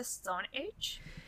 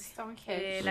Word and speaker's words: Stonehenge: ¿Esto se dice Stone Age? – No tengo la más remota idea de cómo se pronuncia. – Stonehenge.